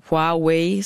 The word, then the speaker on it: Huawei: Huawei, som det udtales, er en kæmpestor kinesisk virksomhed, som producerer udstyr til teleindustrien.